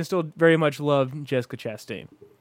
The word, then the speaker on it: Chastain: How do you pronounce that? Is that Chastain? – Chastain. You got it.